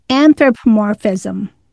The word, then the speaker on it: anthropomorphism: It borders on, let's see if I can say this right, anthropomorphism. – Yes. Yeah, you're right.